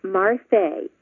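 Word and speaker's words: Marfe: I'm used to telling people how to pronounce it and retelling them. It's Marfae, which rhymes with parfait, that delicious dessert.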